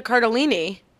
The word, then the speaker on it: Cardellini: It's Linda Cardellini. – Linda Cardellini. Yes. I think that's right. Linda Cardellini.